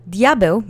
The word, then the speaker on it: diabeł: Anyway, diabeł means devil. It's in its singular subject form. Let's say diabeł together three times slowly. Accent is on the first syllable.